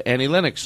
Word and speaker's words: Annie Lennox: we played an Annie Lennox song, didn't we? – We did, yeah.